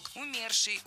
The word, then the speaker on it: умерший: В этом слове ударение должно падать на второй слог. Умерший, умершие, умерших.